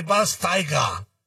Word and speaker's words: Schweinsteiger: ¿Cómo es lo del Zule y Schweinsteiger? ¿Te latió? – No, o sea, la pronunciación perfecta. Schweinsteiger. – Después de 37 años de vida, ya sé cómo se pronuncia.